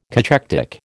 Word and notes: cachectic: American English

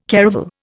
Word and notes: caravel: Indian

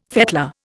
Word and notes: fettler: Indian

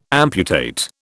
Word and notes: amputate: British English

